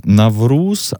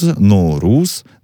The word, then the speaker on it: Навруз: Слово «Навруз» означает «новый день», но сегодня мы уже поняли, что его написание, произношение может варьироваться да, от страны к стране. Я выучил «Навруз», «Ноуруз», «Наурыз». – Вот, Наурыз, это Наурыз. По какому? – Казахстан.